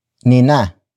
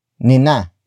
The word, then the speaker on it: Nina: The next word is Nina. Nina. Nina. Nina is spelled as N I N A.